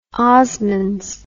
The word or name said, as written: osmonds